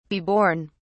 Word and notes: be born: British English

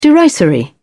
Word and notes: derisory: British English

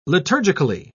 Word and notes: liturgically: British English

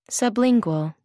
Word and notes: sublingual: American English

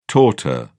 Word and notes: torte: British English

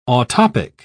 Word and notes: autoptic: British English

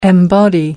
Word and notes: embody: American English

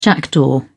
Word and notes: jackdaw: British English

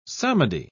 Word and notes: psalmody: British English

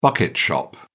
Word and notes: bucket shop: British English

